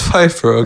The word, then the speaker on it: Pfeiffer: Det uttales Pfeiffer, ok.